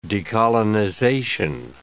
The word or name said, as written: decolonization